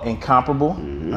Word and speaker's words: NCMPRBL: So he has his own watch company called NCMPRBL, right? I said it right? – Yes, sir. All right, cool.